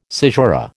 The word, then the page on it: caesura: American English